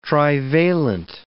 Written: trivalent

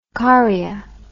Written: correa

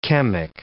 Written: chemic

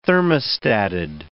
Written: thermostated

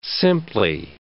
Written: simply

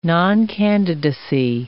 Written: noncandidacy

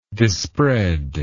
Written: disspread

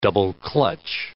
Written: double-clutch

